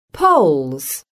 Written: poles

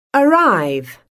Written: arrive

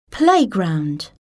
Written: playground

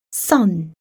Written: sun

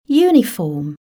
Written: uniform

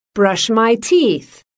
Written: brush my teeth

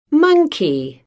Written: monkey

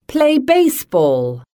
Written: play baseball

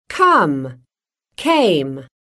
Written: come-came

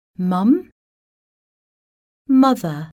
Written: mum-mother

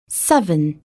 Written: seven

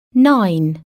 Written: nine

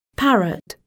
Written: parrot

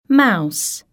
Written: mouse

